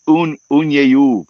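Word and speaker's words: Uniejów: They love you in Poland because you say it and it's Unyeyuv, Poland. And find the fighter who's from Unyeyuv, Poland. Okay. And it's spelled Unijow.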